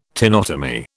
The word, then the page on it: tenotomy: British English